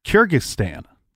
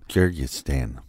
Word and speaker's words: Kyrgyzstan: So he got a wife named Larissa from Kyrgyzstan. Don't make me say that again because I said it wrong the first time. – Kyrgyzstan.